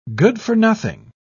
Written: good-for-nothing

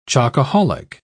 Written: chocoholic

